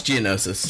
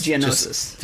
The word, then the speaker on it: Geonosis: technological, and there's lights everywhere, and then you're back out into Geonosis again, and it's like, oh, okay, I still have it's Geonosis. – Geonosis. – Just so we're all on the same.